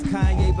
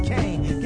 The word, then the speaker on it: Kanye: Now all I need is y'all to pronounce my name. It's Kanye, but some of my plastics still say Kane.